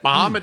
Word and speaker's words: Mahomet: Mahomet, Illinois.